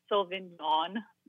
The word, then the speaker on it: Sauvignon: If you think of yawn, like, yawn, Sauvignon.